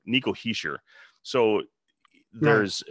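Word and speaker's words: Hischier: And he said that the NHL pronunciation guide, along with his production contact, said it's Heischer. That's what the sheet said.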